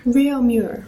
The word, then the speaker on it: Réaumur: So the central figure in the book is this guy, Réaumur. Am I butchering that? – Réaumur.